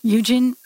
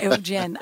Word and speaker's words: Eugen: Nice. Eugen. I like that. Eugen. It's beautiful. But it's spelled E-U-G-E-N with no E at the end. R-O-S-U.